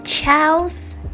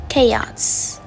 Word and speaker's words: chaos: Rất nhiều người phát âm từ này là chaos, chaos Tuy nhiên lynch trong từ này lại được phát âm là k. Và mặc dù những cách viết không hề có A nhưng trong phiên âm lại có âm tiết A nhá.